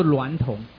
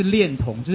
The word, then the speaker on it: 娈童: 这些标准里面有一个叫做娈童啦，我去查了，它第一读音叫做娈童，那么第二读音就是恋童，就是恋爱的恋。